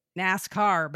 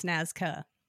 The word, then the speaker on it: Nazca: I really want to say NASCAR, but it's Nazca.